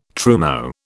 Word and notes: trumeau: British English

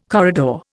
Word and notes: corridor: Indian

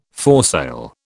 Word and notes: foresail: British English

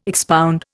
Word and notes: expound: Indian

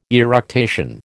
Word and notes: eructation: American English

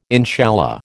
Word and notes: inshallah: American English

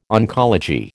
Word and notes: oncology: American English

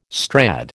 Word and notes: Strad: American English

